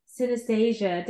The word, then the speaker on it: synesthesia: And now we're going to dive into the work that she's really passionate and what she's doing now is like painting and synesthesia. Did I say that right? – You did. Yep. Good job.